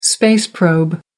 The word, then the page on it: space probe: American English